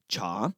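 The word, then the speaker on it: cha (茶): In the Mandarin and Cantonese dialects, the pronunciation is cha. In the Min dialects, it's de or ta. And in the Wu dialects, it's zhou or zhou.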